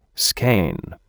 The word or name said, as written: skein